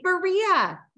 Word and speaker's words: Berea: I don't Baroa. I don't know how to pronounce it. That's why I didn't say it. I'm sorry. – Yes, Megan. – Megan, no, that's great. Berea. Yes, Berea. Yes, spot on, spot on.